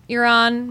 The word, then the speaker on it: Euron: Because it rhymes, Euron, moron.